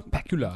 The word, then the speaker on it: Pakula: Pakula. Pakula? Pakula? – Pakula. – I don't know how to say his last name. – Is it like Bakula? – Like Bakula. – Or Dracula.